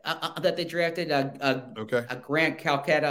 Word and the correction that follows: Calcaterra: Uh, um, I'm mispronouncing the last Calc- name. Calcaterra. Yeah, yeah, yeah Calcaterra. Sorry yeah.